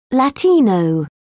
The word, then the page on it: latino: British English